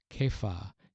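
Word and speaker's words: Kepha (כיפא): You will be called Kepha, is the way it's pronounced in Aramaic.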